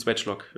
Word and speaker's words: Swagelok: Und zwar besucht uns heute äh, Swatchlock. Ich hoffe, das ist richtig ausgesprochen. Ähm, ja, wir besuchen Swatchlock äh, eigentlich. Das stimmt, also, aber ja.